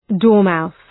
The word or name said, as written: dormouse